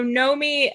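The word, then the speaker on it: Noemí: Is it Nomi? Okay. Good to know. So Nomi,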